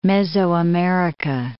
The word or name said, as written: Mesoamerica